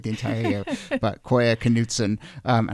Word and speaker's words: Knutson: So it was a nice way to end 2020 and go into the new year with people telling me that it is actually pronounced Knutson, which is a very Minnesota way of pronouncing it.